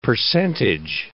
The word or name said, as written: percentage